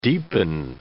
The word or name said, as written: deepen